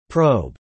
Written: probe

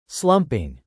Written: slumping

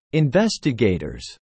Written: investigators

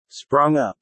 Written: sprung up

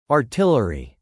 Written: artillery

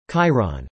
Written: chyron

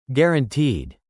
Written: guaranteed

